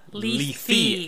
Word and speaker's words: Lethe: Not leafy greens, but Lethe. – L-E-T-H-E. – Like lethal.